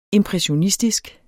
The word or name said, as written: impressionistisk